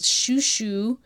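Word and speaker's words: xuixo: So, Tony said, and luckily she wrote how to spell it, it's shushu, and it's XIOXO in Spain, and it's a melt-in-your-mouth pastry that she hasn't found anywhere else in the world.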